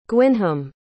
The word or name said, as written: gwynham